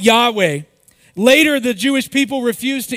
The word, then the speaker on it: Yahweh: We would pronounce this as Yahweh, but really there were no vowels in between this.